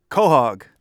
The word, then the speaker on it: Quahog: Quahog. – Quahog, says Larry Spotted-Crow Mann from the Nipmuc people, using this word that the Wampanoag use frequently. People from Rhode Island call it Quahog.